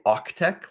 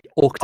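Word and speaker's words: AUCTeX: So were you saying OCTech, like AUC tech? – Oh, did I not pronounce the C? OCTech. – I thought you said org tech. I wasn't familiar with that. OCTech is the only one I've ever used.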